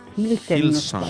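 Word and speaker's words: Hillsong: Se llama, no sé pronunciar bien, Hilsen. – Hilson. Hilson. – ¡Correcto!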